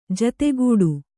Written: ಜತೆಗೂಡು

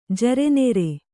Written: ಜರೆನೆರೆ

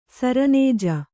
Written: ಸರನೇಜೆ

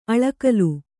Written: ಆಲಿಗಲ್

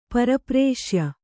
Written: ಪರಪ್ರೇಷ್ಯ

